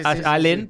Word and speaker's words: Allen: La pronunciación correcta sería Allen porque es en inglés. Pero le quedó Allen, ya está, se castellanizó.